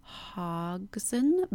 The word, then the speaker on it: Hodgson: And again, Frances Hogg, oh my gosh, can I say her name? Frances Hogson Burnett is who wrote The Secret Garden, but that quote, "She made herself stronger by fighting the wind with the wind."